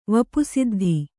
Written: ವಪುಸಿದ್ಧಿ

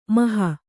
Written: ಮೇಹು

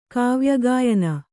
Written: ಕಾವ್ಯಗಾಯನ

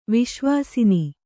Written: ವಾಸವಾಸನ

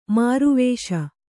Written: ಮಾರುವೇಷ

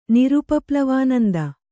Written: ನಿರುಪಪ್ಲವಾನಂದ

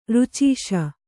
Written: ಋಚೀಷ

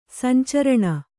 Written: ಸಂಚರಣ

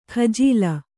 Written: ಕಜ್ಜಾಲಿ

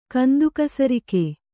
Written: ಗಂದಿಗಸರಕು

